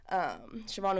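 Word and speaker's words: Siobhán: Yeah, you can guess from the Siobhan and the accent on the A in my name. Siobhan with the B, guys.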